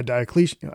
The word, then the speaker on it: Diocletian: And this guy, Diocletian, I don't even know if I'm saying his name right.